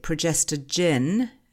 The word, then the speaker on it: progestogen: We're talking about a synthetic progestogen. Is that the right pronunciation of it? – Absolutely.